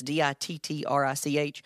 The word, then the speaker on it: Dittrich: On drums and vocals, John Dittrich, I believe is how you pronounce his last name. It's Dittrich.